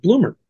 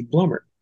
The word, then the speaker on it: Blumert: She says, "Well, Uncle Joey says because it's a German name, it should be pronounced Blumert. And Dad says because we're American, it should be Blumert."